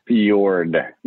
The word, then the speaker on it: Fjord: Your last name, is it pronounced Ford or Fjord? – Fjord. Yeah.